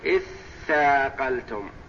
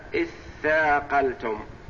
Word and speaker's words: اثاقلتم: تثاقلتم، فأدغمت التاء في الثاء فصارت لا يستطيع النطق بها المرء إلا بعد الإتيان بالهمزة، تقول إث ثاقلتم، لأن الحرفين إذا أدغما أصبح حرفا واحدا مشددا، والمشدد أوله ساكن، والساكن لا يستطيع المرء أن يبدأ به وإنما يأتي بمحرك بحركة قبله فيتوصل إلى النطق في الساكن، فصار إثاقلتم.